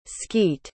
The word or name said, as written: skete